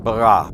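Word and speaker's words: bara (ברא): We're going to extract as much as we can out of the ancient Hebrew word for create, which in Hebrew is pronounced bara, bereshit, bara, Elohim, literally in the beginning God created.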